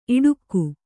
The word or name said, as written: ಇಡುಕು